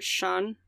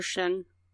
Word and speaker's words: Xian: Pretty much Sean or Shen. Depending on... – I'm just like, it's an X, and I feel like I'm gonna pronounce it wrong, because there's an X. – Just thinking, like... – It's spelled X-I-A-N.